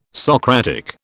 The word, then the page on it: Socratic: British English